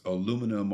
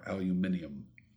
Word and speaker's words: aluminium: And you said the two different pronunciations, so it's aluminum or aluminium.